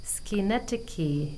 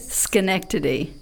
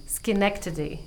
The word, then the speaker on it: Schenectady: You hopefully didn't say that right. – Almost. – No, not even almost. – Okay, so what is it? – Schenectady. – Schenectady, yes. – Very good.